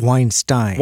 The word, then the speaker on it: Weinstein: Is it Weinstein or Weinstein? – He pronounces it Weinstein.